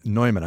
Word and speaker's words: Neumann: Also founder Adam Neumann's Neumann, I pronounced it like the microphone, Neumann.